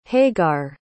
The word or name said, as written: hagar